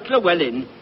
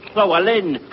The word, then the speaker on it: Llewellyn: It's not Llewellyn, it's Llewellyn, Llewellyn.